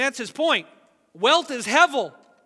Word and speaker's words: hevel: We transliterate it in English, H-E-V or H-E-B. It's actually a soft bait, so it's usually pronounced hevel.